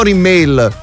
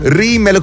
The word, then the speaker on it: Rimmel: Rimmel, quello che si mette sugli occhi, non Rimmel. – Ah, Rimmel. – Rimmel con l'accento sulla prima I.